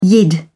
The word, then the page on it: yid: British English